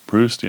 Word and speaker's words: Proust: And, of course, that's straight from, I don't know how you say it, Proust? – Proust, yeah.